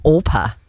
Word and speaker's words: Orpah: Judith actually mentioned, did I know that Oprah, her real name is Orpah? – Yes. – And over the years, people mispronounced her name, and then she just went with it.